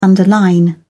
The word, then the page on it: underline: British English